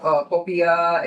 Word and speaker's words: popiah: So, Michael, we just tried your popiah.